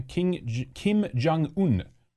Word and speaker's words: Kim Jong-un: So there's speculation that King Jong-un, is that how you pronounce his name? Kim Jong-un is either deceased or he's fallen very, very ill.